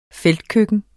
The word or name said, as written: feltkøkken